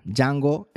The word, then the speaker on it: Django: Django se escribe D-J-A-N-G-O. Django, como la película famosa de Tarantino,